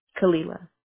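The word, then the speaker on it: Khalilah: It's K-H-A-L-I-L-A-H. – Kalila? – Kalila.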